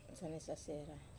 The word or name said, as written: sane sasera